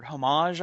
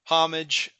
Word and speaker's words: homage: Homage, I believe. – Yeah, if just say Smith. – Just say Smith. – English homage. If you want to sound Frenchy and fancy, homage.